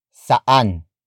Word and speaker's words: saan: The Tagalog word for where is saan. Saan. Saan. Saan is spelled as S-A-A-N.